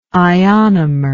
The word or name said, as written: ionomer